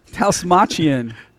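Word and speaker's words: Dastmalchian: And Kurt, played by, I'm not going to try to say his name, Dalmatian? – No, it's Dalsmachian. Come on, man. – Dave the Dalmatian and his employees. Yes. – Dost Malchian.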